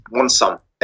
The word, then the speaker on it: Monsum: Monsum. M-O-N. Like monsoon, the rain, like with an M at the end. Monthly sum. You could, yeah. – Okay. Can That's you spell it? – M-O-N-S-U-M.